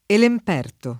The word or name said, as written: Elemperto